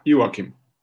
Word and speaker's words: Joachim: Yeah, Joachim.